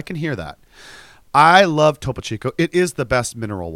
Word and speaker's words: Topo Chico: That fucking Topo Chico. Is that how you say it? – Topo Chico.